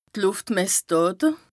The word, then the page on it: Lùftmessdàte: Bas Rhin